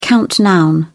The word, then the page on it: count noun: British English